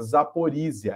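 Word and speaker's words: Zaporizia (Запоріжжя): Que a gente pronuncia na verdade, porque tem um ZH aqui que é só para derrubar jornalista que está fazendo jornalismo ao vivo mesmo. Mas a, a usina é Zaporizia, ela é a maior usina nuclear da Europa.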